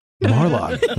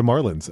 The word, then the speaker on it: Marlon: That's how you say it in Britain. – Is that not how you say it in America? – We say it like the nope. Florida Marlins, as Marlin.